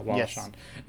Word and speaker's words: Wallace Shawn: Wallace Shawn, did I say that right? Yeah. Wallace Shawn.